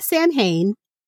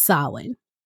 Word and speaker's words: Samhain: So basically it's spelled Samhain. So it's not Samhain. It's Samhain.